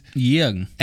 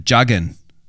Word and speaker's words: Jürgen: And also only because I know that the J is said like that in German, right? But any English speaker would call him Juggen. He's Juggen in English, right?